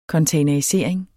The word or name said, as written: containerisering